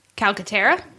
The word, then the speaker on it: Calcaterra: Calcaterra? – Very good. – Did I do that correctly? – I think you did.